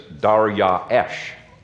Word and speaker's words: Darius: And by the way, you might wonder about the pronunciation of Darius. I've been corrected quite a number of times when I say Darius, and then I switch to Darius, and I get corrected on that. There is no standard pronunciation. You can pronounce it as Darius, Darius, Darius, and none of those are actually the Hebrew pronunciation. Hebrew pronunciation is Daryaesh, okay, and nobody pronounces it that way.